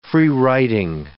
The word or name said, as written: freewriting